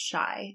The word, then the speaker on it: Chi: Yeah, because she says she put C-H-I, but it's pronounced Shy.